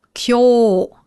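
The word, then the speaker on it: Kyo (今日): Das wird ausgesprochen. Kyo, also mit langem U, das U verlängert grundsätzlich ein O im Japanischen.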